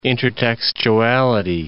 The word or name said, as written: intertextuality